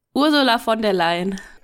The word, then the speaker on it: Ursula von der Leyen: Ursula von der Leyen.